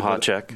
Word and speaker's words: Mahacek: Kelly Marchasek, which I may not be saying her name correctly. – Mahacek. – But I say that again. – Mahacek. – Mahacek. Okay, yeah. It's a couple of silent vowels in there and syllables.